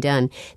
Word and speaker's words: Dunn: Oh, and you can get to the Facebook thing by going to the Kathleen Dunn. Some people had questions about how to spell my name. D-U-N-N is the way to spell it.